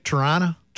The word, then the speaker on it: Toronto: They came to spend, did I say that right? Toronto. I said it like a Winnipegger, didn't I?